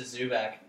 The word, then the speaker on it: Zubac: Zubac. – Yeah, it was a Zubac.